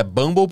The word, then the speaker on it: Bumble: Inclusive, eu sei que a pronúncia certa é Bumble porque me disseram, não porque eu tenho um aplicativo.